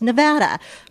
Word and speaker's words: Nevada: And so we have had a 20 plus year debate on how you pronounce Nevada. First 20 years, I said it's Nevada because it's a Spanish word, snow covered mountains.